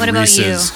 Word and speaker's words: Reese's: I'm glad you said Reese's. You? I'm glad you said Reese's and not Reese's. – Because it's not Reese's? Reese's. Reese's rhymes with feces. That's how you know it's wrong.